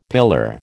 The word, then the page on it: pillar: American English